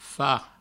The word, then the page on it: far: Provençal; fa